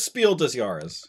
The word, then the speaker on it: Spiel des Jahres: No, it's Spiel des Jahres. – I hope you're right. – But I keep putting an S after Spiels, and that's wrong.